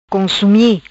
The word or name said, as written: Consumi